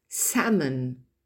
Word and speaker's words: salmon: salmon, almond, donc vous n'entendez pas la lettre L prononcée, mischievous, etc., specifically, specifically, quinoa, ask, move, ne pas confondre avec to move, bouger, qui s'écrit M-O-V-E.